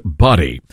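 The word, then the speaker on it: Budde: Who made that booty call? Well, it turns out her name is not pronounced Booty. It's pronounced Buddy. So it is not Booty. It is Buddy.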